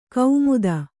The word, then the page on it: ಕೌಮುದ: Kannada